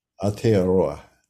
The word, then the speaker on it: Aotearoa: Aotearoa. There we go. That's how you say it. – Yeah. There you go. – You know, with a little roll.